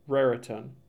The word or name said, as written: Raritan